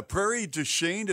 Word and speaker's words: Prairie du Chien: Is it uh, Prairie du Chien? Is that right? Is that how you pronounce it? Yep, that's that's in the other part of Wisconsin.